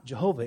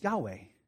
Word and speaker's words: Yahweh: And because the Germans were really the first ones to do this scholarly work, and because in German, J's and W's are pronounced Y's and V's, we end up with the transliteration of Jehovah. So God's name is not Jehovah, it's Yahweh.